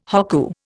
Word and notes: hokku: Indian

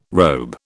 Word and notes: robe: British English

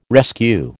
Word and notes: rescue: American English

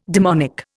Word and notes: demonic: Indian

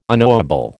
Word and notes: unknowable: American English

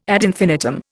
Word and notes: ad infinitum: Indian

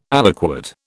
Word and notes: aliquot: British English

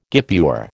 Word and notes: guipure: American English

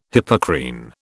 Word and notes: Hippocrene: British English

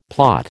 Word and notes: plot: American English

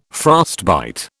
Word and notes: frostbite: British English

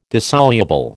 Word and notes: dissoluble: American English

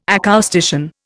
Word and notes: acoustician: Indian

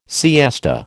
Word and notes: siesta: American English

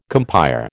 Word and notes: compère: American English

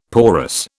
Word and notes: porous: British English

